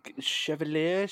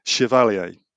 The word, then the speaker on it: Chevalier: Let me try. Chevalier? – Chevalier. – I was way off. – Think Maurice Chevalier, the French entertainer, actor in the 40s and 50s. Chevalier.